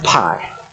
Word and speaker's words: pi: It's actually not pi, it's p, like the letter. That's the Greek letter p.